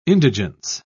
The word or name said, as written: indigence